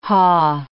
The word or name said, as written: haw